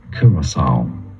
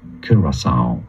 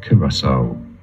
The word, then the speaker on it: Curaçao: Locally in the island it is said as Curaçao, Curaçao, but in English this is generally said as Curaçao, Curaçao, and now you know.